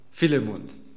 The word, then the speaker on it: Philemon: Und wie spricht man den Namen von Philemon aus in Griechisch? – Philemon. – Seht ihr? Wir lernen was fürs Leben hier.